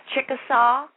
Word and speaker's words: Chickasaw: And that's in, pronounced, is it Chickasaw, is that what you said? – Yes, Chickasaw, Alabama.